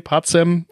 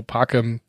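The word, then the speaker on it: pacem: Und ich habe gesagt, nee, nee, Pazem, es ist aber natürlich Blödsinn. Äh, C im Lateinischen wird wie K ausgesprochen, also Parkem.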